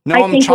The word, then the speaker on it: Chomsky: And, what Chomsky, am I saying it right? – Mm-hmm. – Chomsky.